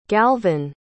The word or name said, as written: galvyn